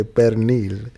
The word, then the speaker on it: pernil: That's why right now she said pernil and she forgot the R. She didn't say pernil.